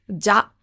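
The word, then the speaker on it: hebi (蛇): The meaning basically means that, so what you need to know is hebi is the Japanese pronunciation. The Chinese pronunciation of snake is ja.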